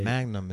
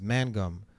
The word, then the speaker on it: Mangum: It's not Magnum. It's Mangum. I was like, oh, all right. – Mangum. – I was like, all right, can you help me pronounce it again? They kept saying, Mangum, not Magnum.